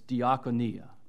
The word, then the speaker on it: diakonia: It has a familiar ring to your ears, even if you don't know Greek, because it sounds like the word deacon. It's diakonia.